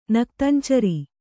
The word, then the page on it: ನಕ್ತಂಚರ: naktan cara; Kannada